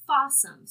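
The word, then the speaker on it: Fawesome: Fossum. – Fossum, spelled like awesome. – Awesome with an F.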